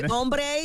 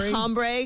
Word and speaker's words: hombre: First of all, the word would be hombre, not hombre, because they don't pronounce the H in spin.